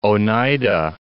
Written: Oneida Lake

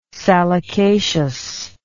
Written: salicaceous

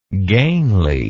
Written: gainly

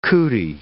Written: cootie

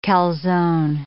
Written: calzone

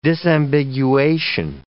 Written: disambiguation

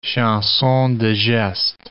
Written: chansons de geste

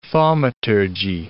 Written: thaumaturgy

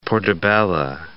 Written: portabella

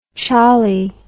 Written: cholly

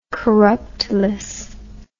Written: corruptless

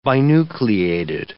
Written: binucleated

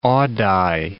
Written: Ogdai

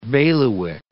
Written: bailiwick

